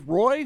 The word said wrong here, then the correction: Roy: What? – Patrick Waugh. – Oh, I'm sorry. – Yeah. But it's spelled Roy.